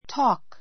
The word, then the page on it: talk: tɔ́ːk; トーク